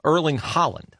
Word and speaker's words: Haaland: Yes, I was late to the World Cup, but the 6'5" Norwegian, Haaland, is that his name? What did I say? Haaland? Erling Haaland. Okay, sorry.